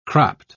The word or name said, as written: cropped